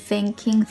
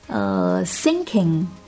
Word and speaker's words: thinking: I'm so done with thinking through 连续的三个吐舌头，不知道你还能不能吃得消呢。提醒一下，小圆圈一横千万不要读成 thinking 之类的 thinking。